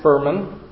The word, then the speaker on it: Furman: Furman, like fur. – Furman Egyetem.